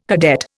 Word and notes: cadet: Indian

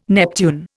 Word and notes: Neptune: Indian

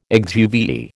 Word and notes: exuviae: American English

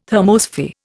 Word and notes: thermosphere: Indian

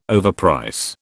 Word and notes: overprice: British English

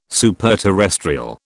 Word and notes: superterrestrial: British English

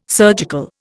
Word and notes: surgical: Indian

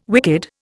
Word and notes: wicked: Indian

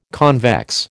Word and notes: convex: American English

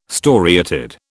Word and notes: storiated: British English

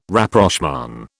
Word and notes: Rapprochement: British English

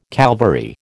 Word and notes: Calvary: American English

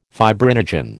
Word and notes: fibrinogen: American English

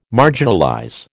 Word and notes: marginalize: American English

